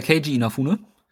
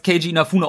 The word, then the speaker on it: Keiji Inafune: Also er, ich bin mir nicht zu 100% sicher, aber ich habe ihn, mein Leben lang kenne ich, dass das als Keiji Inafune ausgesprochen wird, deswegen.